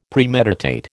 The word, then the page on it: Premeditate: American English